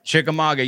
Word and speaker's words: Chickamauga: Chickamauga, yeah.